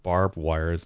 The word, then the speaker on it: barbed: It's barbed wire. – Barbed wire. So I've been saying it wrong for like 10 minutes now.